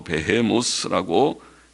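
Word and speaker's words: 베헤모스 (בהמות): 여기에 하마라고 번역된 원어는 영어 성경들은 그냥 원어의 발음대로 베헤모스라고 히브리 발음대로 음역을 해서 그냥 번역을 안 하고 베헤모스라고 그대로 히브리 말로 번역은 번역이 아니지 음역이지 그대로 히브리 발음대로 썼지만은 옛날 학자들은 배의 모습을 코끼리라 그렇게 해석했고 코끼리라고 보았고 근래의 학자들은 하마라고 우리말처럼 그렇게 보았습니다.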